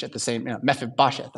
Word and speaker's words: Mephibosheth: It's Mephibosheth. Mephibosheth.